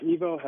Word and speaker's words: EVO: It's EVO Health and Wellness.